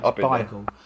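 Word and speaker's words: Beigel: And it is pronounced Bigel.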